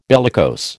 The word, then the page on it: bellicose: American English